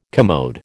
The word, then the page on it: commode: American English